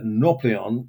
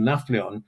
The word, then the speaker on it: Nafplion: Well, interestingly, there was a year or two when it was proposed that Nauplion, in modern Greek pronunciation, Nafplion,